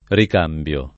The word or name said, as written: ricambiare